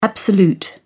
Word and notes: absolute: British English

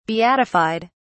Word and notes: beatified: British English

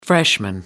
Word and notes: freshman: American English

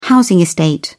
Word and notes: housing estate: British English

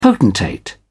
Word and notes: potentate: British English

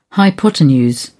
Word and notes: hypotenuse: British English

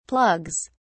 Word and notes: plugs: British English

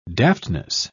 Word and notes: daftness: British English

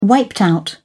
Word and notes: wiped out: British English

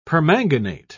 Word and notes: permanganate: British English

